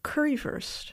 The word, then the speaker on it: currywurst: Is there something called currywurst? – Yes.